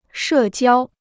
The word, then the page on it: 社交: shè jiāo